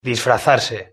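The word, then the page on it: disfrazarse: Spanish (Spain)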